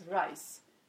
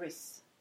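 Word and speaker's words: Rhys: Det är som, det finns ju det här namnet som jag tror uttalas Rice. R-H-I-S. Killnamn. Mm. Jag tror inte man säger Riz. Jag tror det är Rice.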